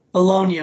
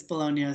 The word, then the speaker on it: Bologna: Well, how do you pronounce that? – Bologna, brother. – It's Bologna? Okay. – Bologna, yeah, it's Bologna.